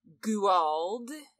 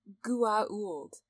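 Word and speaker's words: Goa'uld: Later, once briefcases have magically appeared, they start all the way back with episode 1 and the mission with Kowalski, talking about the Guald, as Kinsey pronounces it. And Jack interrupts him to point out the apostrophe. And then he tells Sam to tell him how to pronounce it correctly. And Sam is like, it's Gua'uld.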